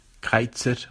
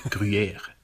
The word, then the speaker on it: Gruyère: Lieber Oli, lieber Jan, die Frage nach dem Käsenamen Greizer oder Gruyère, es ist beides falsch. Es heißt Gruyère.